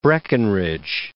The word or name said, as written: Breckinridge